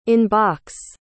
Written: in-box

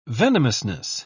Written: venomousness

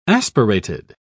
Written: aspirated

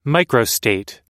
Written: microstate

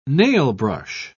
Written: nailbrush

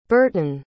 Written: burhtun

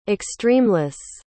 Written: extremeless

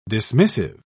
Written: dismissive

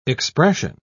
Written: expression

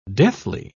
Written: deathly

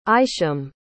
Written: isham

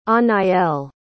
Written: Anaëlle

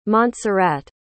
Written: montserrat

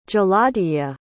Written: jaladhija

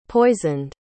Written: poisoned

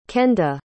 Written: kenda

